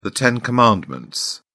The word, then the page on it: ten commandments: British English